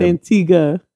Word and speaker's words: Antigua: First of all, it's Antigua. – Antigua. – Yes. – It's Antigua. – Most people don't know how to say it. So, but I'm here to correct it.